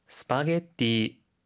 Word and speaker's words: スパゲッティ: スパゲッティ、スパゲッティ、スパゲッティ、スパゲッティ 인 데요。 혹 시 발 음 팁있 을 까요？ – はい、ピザ 라 고할 때 그 때 ザ 발 음 은 Z 발 음이 니 注意해 주 시고 요。スパゲッティ 는 사 이에 조 금이 있 으니 박 자에 도 신 경써 주 세요。